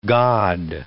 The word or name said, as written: God